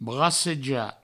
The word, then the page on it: bracejar: brasseja